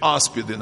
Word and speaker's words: аспид: Но Священное Писание говорит, ух, и яспиды, или аспиды, наверное, аспид, да? Правильно произносится, ядовитый.